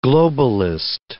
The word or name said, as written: globalist